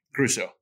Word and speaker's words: Gruesso: Gruesso. – Gruesso. Just like it looks.